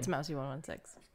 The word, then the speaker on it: Mousie116: Mousie116.